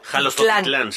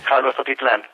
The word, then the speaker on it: Jalostotitlán: Ay, pues qué mal, ya vi que lo pronuncié mal, es Jalostotitlán, sí. – Jalostotitlán.